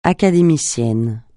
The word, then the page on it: académicienne: francais